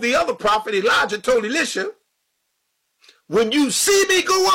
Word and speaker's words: Elisha: And I believe it was Elisha, because both names are closely pronounced. Elijah, S-H-A, and Elijah, J-A-H. So to get a proper distinction between both, I always use Elijah and Elisha.